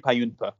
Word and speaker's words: Bajumpaa: Juji Payunpa. That's so yeah. It's it's pronounced Juji Payunpa, but yeah, you can if you if you can remember that, look me up.